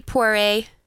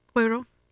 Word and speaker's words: Poirot: Hercule Poirot. – That's not how you say it. – Poirot. – Hercule Poirot. – Yeah, because it's French.